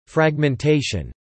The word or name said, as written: fragmentation